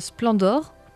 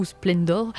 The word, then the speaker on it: Splendor: Oui, c'est sûr, il y a quand même pas mal de petits détails dans ce jeu qui s'appelle Splendor, prononcé à la française, ou Splendor, prononcé presque à l'anglaise.